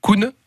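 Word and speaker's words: Kun: le mont Koun si je prononce bien. C'est cela. C'est cela.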